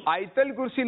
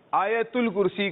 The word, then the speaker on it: آیت الکرسی: آیت الکرسی نہیں کہیں گے, آیت الکرسی کہیں گے.